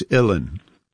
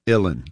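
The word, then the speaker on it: uilleann: It's Illan. Like chillin', Illan.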